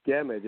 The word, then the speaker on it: Gammage: Phil Gammage is actually how I pronounce his last name.